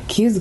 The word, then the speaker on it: Queue: I spell it as in, like, Q-ing, Q-U-E-U-E, but just to, like, stretch it out a little bit, but Q's great.